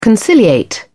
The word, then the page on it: conciliate: British English